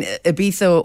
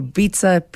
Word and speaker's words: Ibiza: I mean, Ibiza or Ibiza, people call it, you know, two different pronunciations.